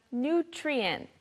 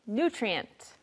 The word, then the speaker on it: nutrient: 아까 영양가 높은이라고 할 때는 nutritious라고 그 강세가 한음절 뒤에 있었어요. 근데 이건 한음절 짧기 때문에 nutrient가 됩니다. 발음 연습해보죠. – Equivalently, equivalently, equivalently. And lastly, we have nutrient, nutrient, nutrient.